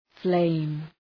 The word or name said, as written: flame